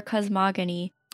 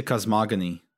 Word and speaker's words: cosmogony: I say cosmogony. I looked it up because I've been saying cosmogony all this time, and uh, turns out I'm wrong. – Oh, it's cosmogony? – It's, it's supposedly a hard G.